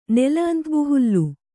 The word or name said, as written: ನೆಲಅಂತಬುಹುಲ್ಲು